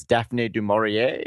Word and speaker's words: Daphne du Maurier: It's Daphne du Maurier. I don't know how to say it. – Very, very good. That's exactly how you pronounce it.